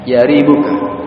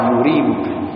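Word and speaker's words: yaribuka (يَرِيبُكَ): Sedangkan al-Imam Ibnu Daqiq al-Aid rahimahullah mengatakan, qauluhu yaribuka, sabda Nabi yaribuka, yang meragukanmu, yarwi bi fathil ya'i wa dhammiha, diriwayatkan dengan difathahkan huruf ya'nya, yaribuka, dan juga dengan didhammahkan yuribuka. Wal fathu afsahu wa ashhu, sedangkan yang difathahkan yaribuka itu lebih fasih dalam pengucapan dan lebih masyhur, lebih terkenal, yaribuka. Tetapi wa yajuzu adzam, boleh juga didhammahkan yuribuka.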